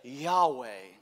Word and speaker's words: Yahweh: We take some vowels from another word for Lord, Adonai, and we mix it into the tetragrammaton, and we say Yahweh. But Jewish rabbis would say more closely to the correct pronunciation would be Yahweh.